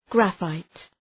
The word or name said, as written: graphite